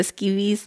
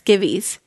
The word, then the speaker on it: skivvies: You said it wrong. It's skivvies. – Oh, skivvies? – No. – Skeevies. – You can't say the short I, can you? – Skivvies.